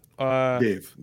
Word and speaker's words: Dev Patel: Is it pronounced Dev? – Dave Patel. Dave? Okay.